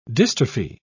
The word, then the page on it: dystrophy: British English